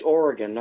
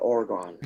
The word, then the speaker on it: Oregon: And it's Oregon, not Oregon.